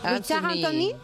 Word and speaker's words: Richard Anthony: Yo diría Richard Anthony pensando en inglés, pero al ser francés, ¿cómo se dice? – Richard Anthony. Claro.